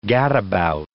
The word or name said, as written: gadabout